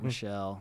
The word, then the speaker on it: Michelle: I thought it was Mark Mitchell for a long time until I heard you say your last name. – I know. You and everyone else, man. It's Mark Michelle. It's a French name.